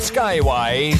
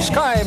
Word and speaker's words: Sky: Het is niet Skyway, zoals in Nederland door sommige discjockeys wel eens gezegd wordt. Maar het is inderdaad gewoon Sky.